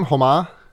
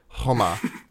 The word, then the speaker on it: Khoma: Okay, so forgive the pronunciation question, but the K is silent in his name, Homa. – Yeah, I don't think there is a K in the Russian version. – Huh. In the subtitles that I was getting, it was K-H-O-M-A, but I mean, I'll defer to you.